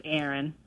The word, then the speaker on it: Erin: My name's Aaron. – Aaron. – Spell that. – E-R-I-N.